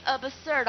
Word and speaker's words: absurd: 但你怎么记呀？absurd 的，看它发音，absurd 的，爱不死的，absurd 的，对不对？